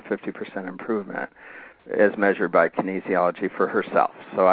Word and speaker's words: kinesiology: And one of the people that I that's a business partner, Darlene, amazing, amazing person, had measured that for her body via kinesiology.